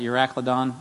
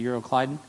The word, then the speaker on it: Euroclydon: And some of you pronounce that Euracladon. I think it's actually Euroclydon.